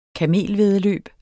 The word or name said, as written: kamelvæddeløb